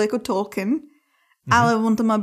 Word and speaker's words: Tolkien: Správně výslovnost Tolkien, uh, omlouvám Tolkien? se, když budu říkat Tolkien, protože jsme na to všichni zvyklí prostě, ale správně a je Tolkien. Tolkien.